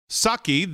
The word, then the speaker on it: Psaki: So let's go into the briefing room today for Jen Psaki. The P is silent.